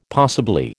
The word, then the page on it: possibly: American English